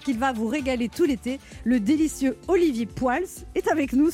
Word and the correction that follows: Poels: Comment La prononciation je C'est Pouls. Pouls. Maintenant, ça sera Poils. Voilà. Bon, après, je pardonne, mais c'est Pouls en Belgique, oui. et en France, on dit Pouls.